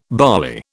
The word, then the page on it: barley: British English